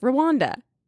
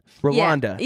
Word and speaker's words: Rwanda: Rwanda. – Okay, so you actually just say it how it's spelled. Rwanda.